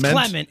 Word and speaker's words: Clement: Verification whether it's Clement or Clement? – It's Clement.